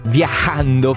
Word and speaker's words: viajando: viajando por el mundo, ma non è che ho tirato su col catarro, è proprio perché la pronuncia corretta è questa: viajando por el mundo, mi è stato detto almeno.